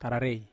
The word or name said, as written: tararei